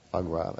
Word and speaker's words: foie gras: Foie gras, that's right. – And extra points for the pronunciation.